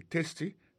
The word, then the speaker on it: tasty: Food, F-O-O-D, ça veut dire manger. Tasty, T-A-S. T-Y, ça veut dire goût, goût dans bouche. T-A-S-T-Y, vous prononcez le testy, ça veut dire goût dans bouche.